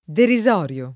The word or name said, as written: derisorio